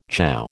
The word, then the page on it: chow: American English